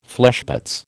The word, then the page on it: fleshpots: American English